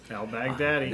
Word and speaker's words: al-Baghdadi: That guy, Baghdadi, did I say it right? – Al-Baghdadi. – There you go.